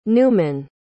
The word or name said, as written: Newman